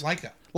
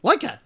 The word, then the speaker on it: Laika: Laika. – Laika, thank you. That was the word I was looking at. I was like, how do you pronounce that word? Laika.